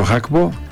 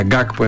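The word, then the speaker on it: Gakpo: Αυτοί έρχονται χωρίς το Χάκπο, που το λέγαμε χρόνια Γκάκπο εμείς, ενώ προφέρεται Χ, Χάκπο, Πεκτούρα, Πεκταράς.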